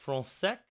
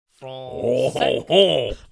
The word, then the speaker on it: Frensek: Frunsec. F-R-U-N-S-E-K. Or actually, it'd be F-R-E-N-S-E-K. And it's pronounced Frunsec. – Frunsec. – Frunsec. Frunsec.